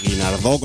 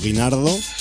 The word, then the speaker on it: Guinardó: Guinardó, con acento en la O, que no Guinardó.